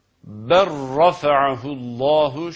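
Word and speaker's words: ber rafa'ahullahu (بل رفعه الله): Dolayısıyla bu kelimelerin okunuşu ber rafa'ahullahu şeklindedir.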